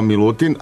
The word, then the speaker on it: My Lutin: I ja dakle se zovem Milutin Petrović, nastupam pod imenom koje se izgovara kao Milutin, ali piše se kao M-Y Lutin, tako da je ovaj, se tako može pronaći na internetu.